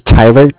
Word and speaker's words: Tyler: My name is Tyler, T-Y-L-E-R, and I am I'm calling from with Cardiff. It's spelled C-A-R-D-I-F-F, as in Frank.